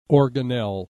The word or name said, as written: organelle